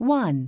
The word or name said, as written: 1